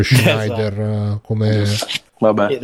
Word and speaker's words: Schneider: penso che sia la pronuncia giusta, Schneider.